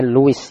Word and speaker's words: Lewis: Artinya Luis ya. Aris ya. Luis ya. Lewis tulisnya nah, bahasa Inggrisnya Lewis. Namanya Luis gitu ya.